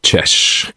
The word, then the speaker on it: Jesh: Dann habe ich lange Jahre eine Band gehabt, die hat Jesh geheissen. Jesh. Schreibt man J-E-S-H.